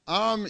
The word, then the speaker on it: Aram: Now Naaman, captain of the army of the king of Aram, A-R-A-M, is that how you say it? Would you say it like that? Aram? That'll do.